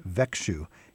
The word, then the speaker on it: Växjö: It's V-A-X-J-O. – And yes. It's pronounced Växjö.